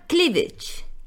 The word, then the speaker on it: cleavage: Al escote se le llama cleavage. Cleavage. Fijaos, se escribe CLEAVAGE, C-L-E-A-V-A-G-E. E. Las palabras que acaban en AG, muchas de ellas se pronuncian edge.